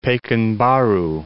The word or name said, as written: Pekanbaru